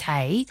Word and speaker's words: hej: Brad in Woolloomooloo says, let's not forget that the Swedish word for hello is hey, H-E-J. Written H-E-J but pronounced hey, and the Norwegian word is hi, H-E-I, pronounced hi.